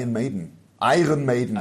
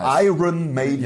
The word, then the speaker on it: Iron Maiden: Dann war ich da bei Iron Maiden. – Entschuldigung, ich habe es total falsch ausgesprochen. Iron Maiden.